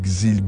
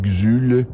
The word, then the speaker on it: Xul: X-U-L, Xul, Xil, Xul, prononcez-les comme vous le voulez, c'est Xul.